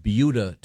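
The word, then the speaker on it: Buda: we have yet to get to sports, but we were discussing a very interesting news item from Beulah, Texas.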